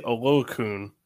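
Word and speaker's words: Oluokun: Okulam. – I was about to say, it's Foyse Olokun.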